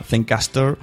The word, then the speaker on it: Zencastr: Bueno, pues ahí teníais a los chicos de Zencaster, que se escribe Z-E-N CAST terminado en R, Zencast. La verdad es que para los españoles va a ser un poco dilema, pero bueno, quedaros con la palabra Zencaster,